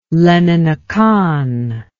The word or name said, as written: leninakan